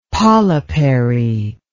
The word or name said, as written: polypary